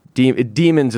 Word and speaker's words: daemons: They're called daemons. Demons is what it sounds like when the British say it, but it's spelled D-A-E-M-O-N-S.